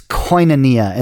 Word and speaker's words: koinonia: it's koinonia.